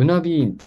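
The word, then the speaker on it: Underbyen: うんえっとアンダーバインって書いて、多分ウナビーンって読むんですけど、はい。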